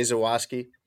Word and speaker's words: Zawoski: Yeah, Rick Camp from the I'm Fat podcast with Jay Zawoski. Am I pronouncing that right? I don't know him. – Yeah. – Okay. Jay Zawoski.